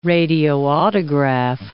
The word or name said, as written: radioautograph